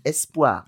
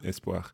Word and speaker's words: espoir: So it sounds more like an E grave, doesn't it? Espoir. – Espoir. – Okay, it's not espoir.